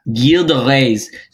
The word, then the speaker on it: Gilles de Rais: Gilles de Rais.